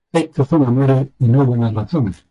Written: razones

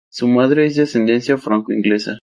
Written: franco

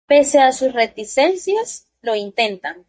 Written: intentan

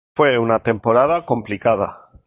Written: complicada

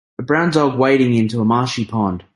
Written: wading

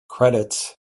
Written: credits

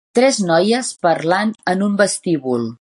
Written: parlant

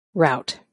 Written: RT

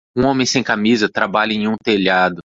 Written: telhado

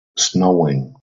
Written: snowing

